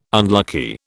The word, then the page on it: unlucky: British English